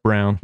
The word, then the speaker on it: Braun: This spelled man Braun, pronounced Brown.